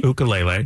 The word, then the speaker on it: ukulele: the pronunciation of is actually ukulele. – Ukulele.